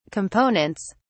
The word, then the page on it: components: British English